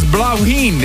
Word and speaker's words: Bláithín: He wrote, it's pronounced as Blauheen. It's an Irish word for little flower, and what a beauty it is.